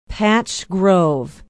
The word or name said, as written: patch grove